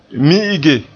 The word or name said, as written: mi'ige